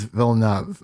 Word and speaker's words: Villeneuve: All right, and we are back with our Dune 2021 review, directed by Denis Villeneuve. I believe it's Villeneuve. I've heard of Villeneuve, but I believe it is Villeneuve.